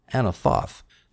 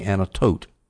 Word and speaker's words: Anathoth: So then I look at the Hebrew, and yes, the tau does not have a dagesh, doesn't have a dot in it, so it should be pronounced th, anathoth. So then I look at Strong's Concordance, and they have it all pronounced for me, anatot. Excuse me, anatot. The O is long.